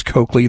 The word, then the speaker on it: Coakley: So if you'd like to connect there or on Twitter, Vince Coakley, that's C-O-A-K-L-E-Y.